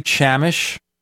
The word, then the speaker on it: Chamish: Welcome back to the Paracast, where we introduce for the first time in the show Barry Chamish. I hope I pronounced his name correctly. So, it's Barry Chamish.